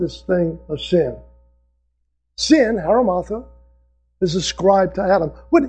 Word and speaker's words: hamartia: The Greek word for sin is a word, haramatha, is the way it's pronounced, as best I can tell. H-A-M-A-R-T-I-A.